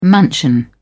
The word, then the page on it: mansion: British English